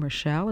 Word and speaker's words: Rochelle: My name is Rita, and that's spelled R-I-T-A, and my last name, Rochelle, is spelled R-O-C-H-E-L-L-E.